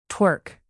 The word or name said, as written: twerk